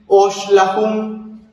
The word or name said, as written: óoxlajun